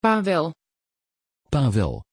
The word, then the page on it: pawel: Dutch